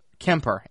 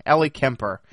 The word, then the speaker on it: Ellie Kemper: Is her name, I believe is how you say it. Kemper. Ellie Kemper.